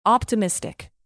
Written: optimistic

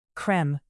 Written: creme

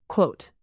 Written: quote

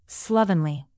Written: slovenly

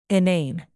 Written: inane

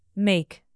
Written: make

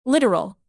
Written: literal

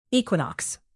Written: equinox

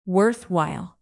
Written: worthwhile

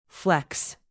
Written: flex

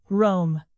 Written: roam

